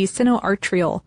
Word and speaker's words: sinoatrial: Those are the sinoatrial, I said that right, yes.